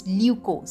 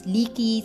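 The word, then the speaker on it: lykke: It's L-Y-K-K-E. And how it's pronounced, it's Lyuko. Some people call it Leaky, some people say Lyugo. Whatever you want to pronounce, but remember, Lyuko is all about happiness.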